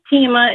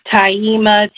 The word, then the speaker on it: Teima: Hi, my name is Tima. It's spelled T-E-I-M-A, and I absolutely love my name. My mom, we all have unique names, but all through school, my teachers always mispronounced my name, Taima, Tiaima, Thelma.